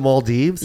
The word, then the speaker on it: Maldives: Oh, is that the Maldives? – Yes.